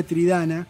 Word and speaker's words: Triddana: Es decir, Tridana viene de la palabra irlandesa que se escribe Troideana y se pronuncia Tridana, que quiere decir luchas.